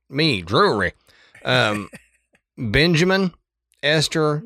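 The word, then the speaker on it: Drewry: Now, the patriarch, John Bell, and his wife, Lucy, moved their large family that included their children, John Jr., Drury, which is an interesting name, Drew R.Y., Drury.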